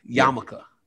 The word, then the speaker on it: yarmulke: Yarmulke. You say yarmulke, but spell it like yarmulke. – Yeah, the L is silent.